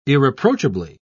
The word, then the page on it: irreproachably: British English